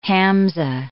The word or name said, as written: hamzah